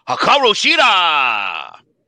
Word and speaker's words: Hikaru Shida: I will take the champion, Hikaru Shida!